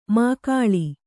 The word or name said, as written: ಮಾಕಾಳಿ